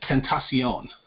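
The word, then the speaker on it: XXXTentacion: I kept calling it Ex-Tentacon. It's Tentacion.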